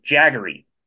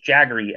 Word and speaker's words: Jagari: His nickname is Jaggery. It's spelled J-A-G-A-R-I, but spelled or pronounced Jaggery, as in he was a big fan of Mick Jagger.